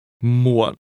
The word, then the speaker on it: muộn: Muốn The word for want is very similar to the word late used to say later than that, but want is pronounced with a high rising tone. Muốn. And the word for late is pronounced with a low, falling, broken tone. Muộn.